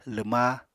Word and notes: lwma: apatani

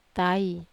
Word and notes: tai: Wamesa

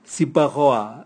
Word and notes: sibwagoa: Raga

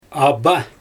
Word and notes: aba: Xyzyl

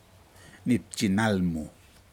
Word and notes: nipjin nalmu: Aneityum

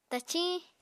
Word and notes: taciñ: Apatani

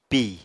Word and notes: pì: apatani

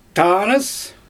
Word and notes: taanɯs: Xyzyl

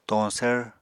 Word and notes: toñsvr: Apatani

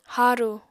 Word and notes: harù: apatani

